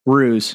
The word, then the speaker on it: ruse: It's a big rouse. Yeah, that's how you say it. Yeah, rouse. – Ruse.